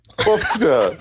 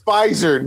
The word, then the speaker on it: Pfizer: Pufta. FDR. Pfizer. Pfizer, not Pufta.